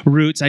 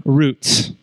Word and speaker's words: roots: I pronounce this word roots, but I have heard another pronunciation since moving to Ohio. Roots. There's a like soot, roots.